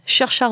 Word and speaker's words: Saoirse: Saoirse Ronan. – Ah, j'adore, c'est parce que ça s'écrit Saoirse en vrai. Hein. Donc on dit Saoirse.